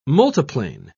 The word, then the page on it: multiplane: British English